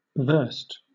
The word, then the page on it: versed: British English